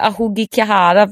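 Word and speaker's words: Ahugikihara (青木ヶ原): Som heter, nu har jag kollat upp uttalet här till och med, för att jag, annars hade det blivit fel. Ahugikihara brukar man säga då, om det här.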